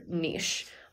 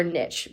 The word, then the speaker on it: niche: So I guess what I want to get into a little bit is on the topic of finding your niche or niche. We haven't decided which niche rhymes with which. Rhymes with get rich.